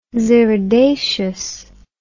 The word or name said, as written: xyridaceous